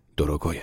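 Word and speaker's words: دروگوی (дорогой): به من حالی کرد که پی روسی ر تلفظ می شود و اصل کلمه دروگوی است، یعنی عزیز.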